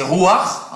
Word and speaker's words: ruach: Esprit, ça veut dire, ça se prononce, c'est ruach en hébreu, et ça veut dire le vent, le souffle.